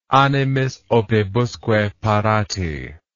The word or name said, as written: animis opibusque parati